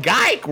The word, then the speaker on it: Geik: But, y'all, I am referring to none other than Pat Geik rhymes with Dyke.